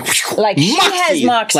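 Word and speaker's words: Moxee: It's like two syllables. Moxie. It's M-O-X-E-E. So you say like this. Pay attention. Everybody gets it wrong.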